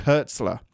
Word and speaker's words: Hertzler: Hertzlinger, Hertzl- Hertzlinger, Hertzlinger, Hert- gosh dang it, how do you pronounce his name? J.G. Hertzler. Um, he's so funny.